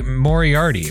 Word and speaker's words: Moriarty: Today we have a very special guest, Miss Mary Moriarty. Am I pronouncing that correctly? – Yes, just like Sherlock Holmes.